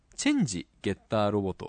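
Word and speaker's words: 真ゲッターロボ: はいはい、新ゲッターロボ、実はですねこれ、はい、チェンジゲッターロボと読みます。新と書いてチェンジと読むようですね。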